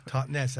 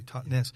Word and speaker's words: Totnes: Totnes, as you say it properly, Totnes.